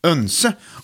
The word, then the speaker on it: oense: Men det var första gången vi var väldigt oense om fikat. – Ja. – Vi är helt oense. Oense. Önse. OE blir ju egentligen ö, vet du. Bjoer till exempel. Så oense uttalas önse på svenska.